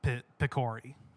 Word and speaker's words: Picori: It actually should be Pikoru or Pikor, but I guess they change it to Pikori. But that gives you a general basis of how it's supposed to be pronounced. It is Pikori.